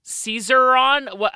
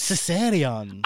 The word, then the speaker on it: Caesarion: Caesarion.